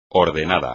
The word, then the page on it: ordenado: espagnol